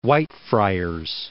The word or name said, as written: Whitefriars